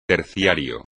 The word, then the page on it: terciario: espagnol